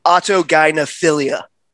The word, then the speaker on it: autogynephilia: How do you say that again? So I just don't want to say something stupid. – Autogynephilia. – That's why I say AGP. – It rolls off the tongue. Autogynephilia.